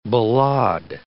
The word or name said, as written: ballade